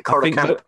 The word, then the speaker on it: Curragh: The Curragh Camp, yeah, the Curragh Barracks.